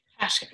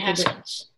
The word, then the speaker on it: Haskins: You have Hoskins now and Kyle Allen. So, Haskins. – I don't – Dwayne Hoskins, the Ohio State. – Dwayne Haskins.